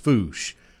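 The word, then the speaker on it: Fouche: It's spelled, where I've come from, it's pronounced Foosh. I hope I'm saying her name right. It's Foosh Parker, F-O-U-C-H-E. I think that's the way you would pronounce it. At least that's the way I've always heard it pronounced.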